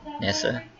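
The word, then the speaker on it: Nyssa: I think my favorite characters that actually do it are Ian and Barbara and Nessa. Nessa? That's how you say it.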